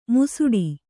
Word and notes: ಮುಸುಡಿ: musuḍi

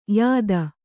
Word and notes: ಯುತಿ: Kannada

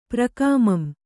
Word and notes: ಪ್ರಕಾಮಂ: prKāmam

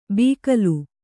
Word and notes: ಬೀಕಲು: bīkalu

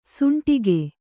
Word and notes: ಷಂಡಕ: ṣaṇḍaka

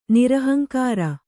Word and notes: ನಿರಹಂಕಾರ: Kannada

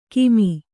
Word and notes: ಕಮ್ಮಿ: kammi

